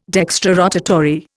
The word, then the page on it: dextrorotatory: Indian